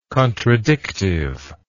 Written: contradictiveness

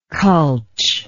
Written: cultch

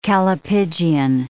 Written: callipygian

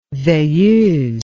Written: veilleuse

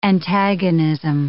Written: antagonism

